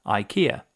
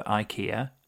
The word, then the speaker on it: IKEA: So for forever and a day, everybody in the UK has said IKEA. And fairly recently, we started getting some adverts for IKEA where they started pronouncing it IKEA.